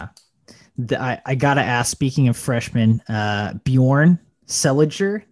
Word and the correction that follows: Seeliger: did I say his name right? – think it's Seeliger. – Seeliger. So no, I didn't.